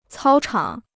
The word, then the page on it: 操场: cāo chǎng